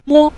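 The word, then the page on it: mo: tone 5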